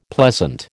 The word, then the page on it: pleasant: American English